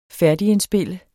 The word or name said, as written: færdigindspille